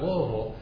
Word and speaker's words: Louisville: And it was right, and that's how you say it, by the way, those of you who are not from Kentucky, it's Louisville. It's not like Al Michaels says, Louisville, Louisville.